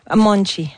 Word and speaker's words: Monte: On dit Monte? – Monte. – Monte, ça s'écrit M-O-N-T-E pour les intéressés.